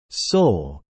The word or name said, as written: sole